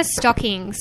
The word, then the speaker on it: Stocking: It's Hannah Stocking. So that's Stockings. A singular stocking.